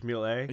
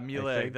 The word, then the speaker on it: Méliès: So this is a guy that has written a few historical books, sometimes about film, you know, like, how do you say that name? Is it Méliès, the French? – Georges Méliès? – Yeah, Méliès, thank you.